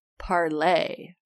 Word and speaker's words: Parler: It turns out that Parler, I learned this today thanks to Thomas Chatterton Williams' Twitter account, Parler is supposed to be pronounced Parlay.